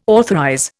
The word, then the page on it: authorize: Indian